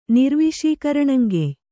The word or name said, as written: ನಿರ್ವಿಷೀಕರಣಂಗೆಯ್